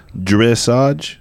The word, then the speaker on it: dressage: Dressage? – Yeah. – Am I saying it right? – Uh-huh. – Dressage? – Yeah.